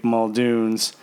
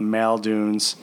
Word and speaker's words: Muldoon's: One of our favorite restaurants in a place we all got to know each other, Irish Pub in downtown Wheaton, named Muldoon's, or to some people who don't know how to pronounce it, they call it Maldoon's, which always annoyed the crap out of me.